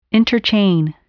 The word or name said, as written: interchain